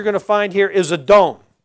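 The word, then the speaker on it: Adon (אָדוֹן): This is a silent letter, this Aleph. with a Kamatsko-Don in there, that's A sound. D-O-N. Adon.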